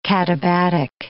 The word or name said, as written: katabatic